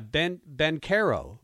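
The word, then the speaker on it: Banchero: And that kid, was his name Panchero? – Yeah. – It's tough to say his name. But yeah, Bencaro. – Bencaro. – Paolo Bencaro.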